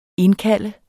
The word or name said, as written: indkalde